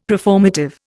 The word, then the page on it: preformative: Indian